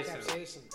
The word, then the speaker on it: capsaicin: Scapperson? How do you say that? Crystals? I'm very ill. – Capsaicin.